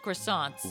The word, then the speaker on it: croissant: They got the best croissants. – We love New yes. – York. – Yes. – And it's pronounced croissant. – Croissant. Croissant. Yes. Well, okay. Crescent if it's Pillsbury.